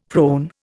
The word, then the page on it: prone: Indian